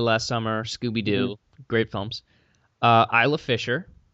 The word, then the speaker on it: Isla: Isla Fisher, pronounced Isla.